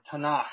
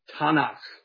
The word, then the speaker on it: Tanakh: And you got to use that little bit of sound in the back of your throat when you say this Hebrew word, Tanakh. It's just fun to do. That's how they pronounced it back then. They got Ta from Torah, Na from Navim, and Cha from Chatavim. So, Tanakh.